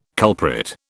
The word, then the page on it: culprit: British English